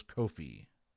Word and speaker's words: kowhai: It's spelled K-O-W-H-A-I, but the pronunciation I looked up earlier was Kofi.